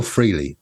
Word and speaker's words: Frehley: Is it Frehley or Frehley? – Frehley. – Frehley. I've been saying it wrong all my life. I tend to agree with Ace Frehley. I don't think you're supposed to emphasise the Frehley, are you?